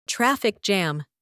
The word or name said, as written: traffic jam